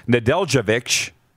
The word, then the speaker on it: Nedeljkovic: And in goal for Detroit, Alex Nedeljkovic, did I say that right? Yeah.